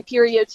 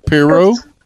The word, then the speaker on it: Perriot: It's pronounced Piro is the proper pronunciation. I used to say Perio too. – So, Piro? – Yes.